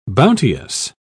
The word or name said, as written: bounteous